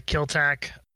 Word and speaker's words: Killtack: I go by Killtack, or as most people pronounce it, Kill a C. The T is silent and invisible, I guess.